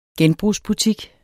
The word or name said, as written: genbrugsbutik